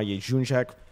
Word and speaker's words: Jędrzejczyk: But we have Ken Shamrock joining us in about 7 minutes, so I want to move on to the Joanna Jędrzejczyk.